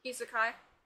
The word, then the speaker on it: Isekai: or Isekai animes, I can't remember how you say it at the moment, or... – Isekai? – Yeah. I thought so. I knew that first saying didn't sound right.